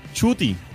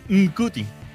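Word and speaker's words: Ncuti: Se pronuncia Chuti. Ah, de verdad, no es Nkuti.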